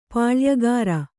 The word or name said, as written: ಪುಲಿಯುಗುರ್